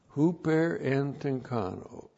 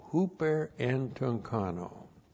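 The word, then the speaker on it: huper en tunkano (ὑπερεντυγχάνω): The key word here is huper en tunkano. Huper en tunkano. I got to do a little better job than that. There, see, some of you said, whoa. Huper en tunkano, H-U-P-E-R-E-N-T-U, two gammas together, the first one transliterated as an N, C-H-A-N, long O, huper en tunkano.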